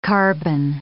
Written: carbon

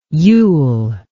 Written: yule

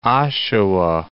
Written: Oshawa

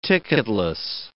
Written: ticketless

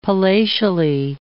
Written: palatially

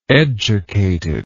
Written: educatedness

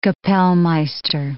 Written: kapellmeister

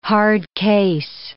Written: hardcase

